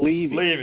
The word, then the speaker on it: Levy: By the way, it becomes more credible when you pronounce the guest last name properly. So it's Mitchell Levy.